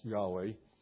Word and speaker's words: Yahweh: Actually, the Hebrew doesn't even have any vowels, so we see that it's, but it is pronounced Yahweh.